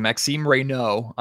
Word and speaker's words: Maxime Reynaud: Yeah, I'm working on my French, and I'm working on the pronunciation. Maxime Reynaud. I was mistakenly pronouncing the D last night.